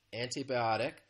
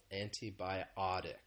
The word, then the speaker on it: antibiotic: So you've noticed I've been saying antibiotic. The stress is on that fourth syllable, antibiotic.